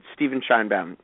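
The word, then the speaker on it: Stephen Sheinbaum: You did, Steven Scheinbaum.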